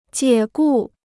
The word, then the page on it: 解雇: jiě gù